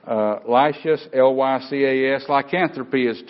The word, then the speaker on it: lycanthropy: It's called lycanthropy. And it's spelled L-Y-C-A-N-T-H-R-O-P-Y.